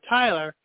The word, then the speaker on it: Tylar: Yeah, I just try to – I jokingly have announced myself like Tyler, but I spell it the way Tigger spells it. T-Y-L-A-R.